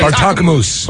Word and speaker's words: Bartokomous: How do you say Bartokomous.